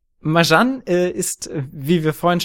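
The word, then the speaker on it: Marjane: Ich glaube, sie heißt nämlich Marjan. Du hast diesen diesen das das J viel zu deutsch ausgesprochen, Jan.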